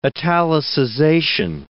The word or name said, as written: italicization